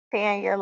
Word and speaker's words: Tanya: It's Tanya, like the color tan.